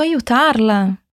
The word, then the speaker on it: aiutarla: Ah, e o A é bem aberto, como o A da nossa água. Então, como eu digo como posso ajudá-lo? – Como posso aiutarla? – Vamos subir mais um degrauzinho?